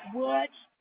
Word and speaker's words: Woods: F-R-A-N-C-E-S-C-A. – And may I have your last name? – Woods, W-O-O-D-S.